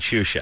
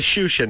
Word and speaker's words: Xuxa: All right, Drew, get on the internet and go find Shusha. – How do you spell it? – X-U-X-A. – X-U-X-A?